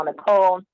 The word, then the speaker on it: Nichole: So my name is Chanel Nicole. My YouTube channel is at Nicole's Place. Nicole is spelled NICHOLE.